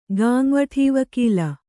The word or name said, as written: ಗಾಂವಠೀವಕೀಲ